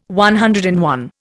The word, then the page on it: CI: Indian